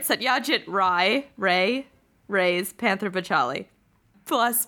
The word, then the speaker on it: Ray: Satyajit Ray's Rai? Ray? Satyajit Rai? – Are we going pure Bengali pronunciations? If so, then it's Rai. – But Jamie shamed me into calling him Ray months ago, so who knows? – I'm often wrong, but never in doubt.